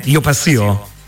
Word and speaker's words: Io passio: Io passio, giusto, l'ho detto bene Roberto, perché io ogni tanto entro in crisi, l'ho detto bene? Io passio? – Sì, assolutamente bene, è un nome un po' dialettale per dire io passeggio,